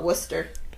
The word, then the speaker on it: Worcester: You know what they call the city in Massachusetts? – Thank you. – They just call it Worcester. – Worcester. – And that's how it's spelled. Worcester.